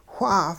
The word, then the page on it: wha: hupa